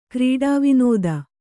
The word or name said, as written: ಕ್ರೀಡಾವಿನೋದ